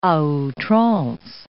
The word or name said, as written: a outrance